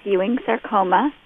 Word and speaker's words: Ewing sarcoma: Is it Ewing sarcoma cancer? – Yes, it is. It's Ewing sarcoma.